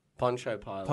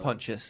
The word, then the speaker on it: Pontius: The issue but, I had was, yeah, was this character is Pontius Pilate and you introduced... – He can't do a musical? – Poncho. – Poncho Pilate. – Pontius.